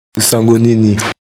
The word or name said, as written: nsango nini